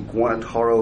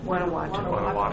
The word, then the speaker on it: Guanajuato: the Guanataro mural on the Cal Guanataro. Am I saying that right? – Guanajuato.